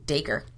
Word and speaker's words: Dacre: Dacre.